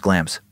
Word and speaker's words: Glamis: So hard not to say Glamis. It's spelled Glamis, clearly G-L-A-M-I-S, pronounced Glams. No idea why.